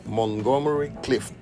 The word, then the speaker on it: Montgomery Clift: He mencionado este nombre diez veces en el día y nunca lo dije bien. Montgomery Clift, ahora sí.